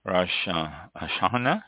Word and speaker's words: Rosh Hashanah: of Rosh Hashanah, is how it's pronounced, Jewish holidays.